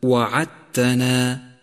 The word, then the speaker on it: وعدتنا: سابعا، لاحظ الدال الرمادية في كلمة وعدتنا وعدتنا، فهي لا تلفظ لأنها مدغمة بما بعدها كما سيأتي.